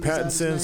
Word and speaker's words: Pattinson: Robert Patterson. – Pattinson, something like that.